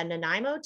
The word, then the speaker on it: Nanaimo: And then I went to Nanaimo too, like on a.